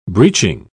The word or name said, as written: breeching